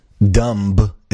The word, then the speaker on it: dumb: Dumb is not with the B. You don't pronounce the B in dumb.